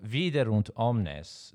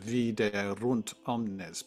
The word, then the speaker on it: Viderunt omnes: And so in what we tend to use today is Italian pronunciation, Viderunt omnes. But some of the recordings I've heard, and I'm not sure which version, you know, the choir is going to do in our concert. But if you sing it with French pronunciation, it's Viderunt omnes, and so that you get this sudden change of tone from singing everything on e v e e e e, and then suddenly you go u, The whole kind of timbre of the thing shifts suddenly.